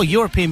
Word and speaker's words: European: It's, well, European begins with an E. It's a vowel. Europe. – Yeah, but it's pronounced with a Y, isn't it? – The matter.